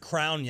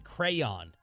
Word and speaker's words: crayon: So my brother said crown yesterday. I said crayon. – He's one of those. – One syllable versus two syllables.